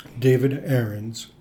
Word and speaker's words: David Ahrens: David Ahrens, A-H-R-E-N-S.